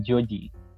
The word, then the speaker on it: Joji: ¿Cómo se escribe? Hoji. Se escribe Joji porque tiene ascendencia japonesa y australiana.